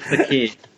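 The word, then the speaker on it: Fekir: Nabil Fakir.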